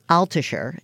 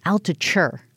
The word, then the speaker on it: Altucher: We're talking to James Altucher. It is the Jill on Money Show. Oh, I said it wrong. Altucher. – Yeah, Altucher.